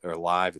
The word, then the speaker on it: LIV: But yeah, they have that coming up, that the Live or Live, it's like LIV. I'm not sure how to pronounce it. Okay, LIV, like Liv Morgan from WWE.